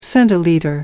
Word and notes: centilitre: American English

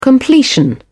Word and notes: completion: British English

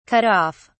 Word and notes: cut off: British English

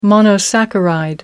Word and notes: monosaccharide: American English

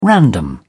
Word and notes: random: British English

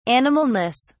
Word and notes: animalness: British English

